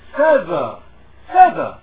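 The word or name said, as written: feather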